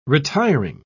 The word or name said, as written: retiring